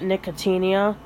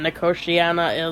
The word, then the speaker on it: Nicotiana: All my life I had pronounced it Nicotinia, but I had started working at a greenhouse and we sold an ornamental variety of it, which I'd heard pronounced as Nicotiana, and I wasn't sure what was right, and we actually looked it up just today, and Nicotiana is correct.